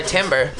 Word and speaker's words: timbre: Timbre. Okay, I was like, my timbre. – How do you pronounce it? – Timbre. – It's timbre. – T-B-R-E.